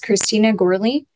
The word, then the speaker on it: Christina Gourley: Hello, my name is Christina Gourley, C-H-R-I-S-T-I-N-A, last name G-O-U-R-L-E-Y.